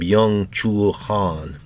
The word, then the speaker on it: Byung-Chul Han: Try to pronounce his name, Byung-Chul Han, B-Y-U-N-G hyphen C-H-U L, last name H-A-N, Byung-Chul Han.